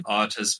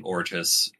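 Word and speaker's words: Ortus: I'm saying it Otis because Moira Quirk is, I guess, I assume British. And so that's how she pronounces it in the audiobook.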